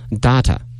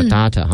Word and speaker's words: data: 当然，这个 data 也可以念成什么 data 啊？有些地方叫 data 哈、澳洲应该念的 data。美国人叫做 data 啊，所以呢，这个词的发音啊是有不同的国家不同的念法啊，raw data、 raw data 都可以。